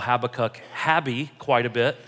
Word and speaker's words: Habakkuk: I've taught this book of the Bible a couple of times, and I've been saying it incorrectly for about 10 years now. I always said Habakkuk. It's Habakkuk.